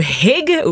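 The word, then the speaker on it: hygge: C'est un peu la mode dans les pays scandinaves avec le fameux hug. ou je sais pas mm-hmm. comment on le prononce. Comment on le dit? – Hug.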